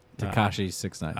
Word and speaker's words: Tekashi 6ix9ine: Tekashi 6ix9ine.